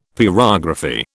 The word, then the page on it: pyrography: British English